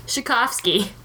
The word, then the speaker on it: Tchaikovsky: Tchaikovsky. There we go. – Oh. – In particular, there's a T in front of it and it really threw me off.